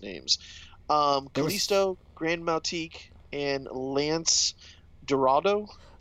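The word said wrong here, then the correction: Gran Metalik: Grand Metalik and yep.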